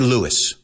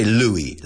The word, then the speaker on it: Louis: Louis, we don't say Louis. We say Louis, like the French pronunciation.